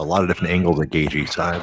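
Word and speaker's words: Gaethje: Yeah, and he comes at you from all kinds of different angles, which will be interesting to see how I can't say his name right. Gagey, is that right? – Yeah, it doesn't look like it sounds like Gagey, but yeah, that's what it is.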